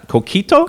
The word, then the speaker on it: coquito: And Pedro brought, how you pronounce, COQUITO? – There you go.